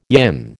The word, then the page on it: yin: American English